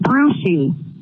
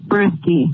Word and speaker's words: Bruschi: Not Bruschi. It's Teddy Bruschi.